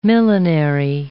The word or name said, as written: millinery